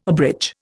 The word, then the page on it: abridge: Indian